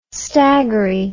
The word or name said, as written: staggery